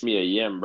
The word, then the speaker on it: Mia Yim: We got Shayna Baszler versus Mia Kim. My winner right now is Mia Yim, bro. – My winner is Baszler. – Don't be mispronouncing my girl's name.